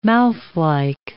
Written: mouthlike

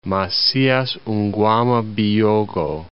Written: Macias Nguema Biyogo

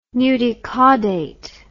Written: nudicaudate

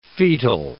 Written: fetal

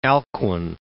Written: Alcuin

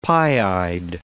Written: pie-eyed

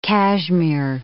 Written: cashmere

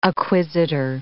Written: acquisitor

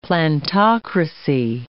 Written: plantocracy

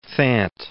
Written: Thant

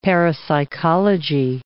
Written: parapsychology

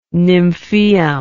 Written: nymphaeum

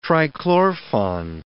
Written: trichlorphon

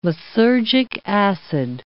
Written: lysergic acid

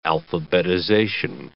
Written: alphabetization

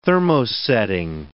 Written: thermosetting

